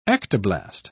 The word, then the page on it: ectoblast: British English